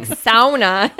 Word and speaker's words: sauna: That is the proper pronunciation. – Sauna. – It's sauna. – Sauna. – It's the Finnish proper.